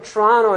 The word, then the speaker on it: Toronto: If I'm saying that correctly, it's not Toronto, it's Toronto. That's how they say it. So he's from Toronto and he's working at a small engineering firm of six people.